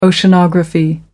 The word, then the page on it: oceanography: American English